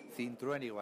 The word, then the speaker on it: Cintruénigo: ¿Pero cómo se pronuncia eso? Cintruénigo, así de sencillo.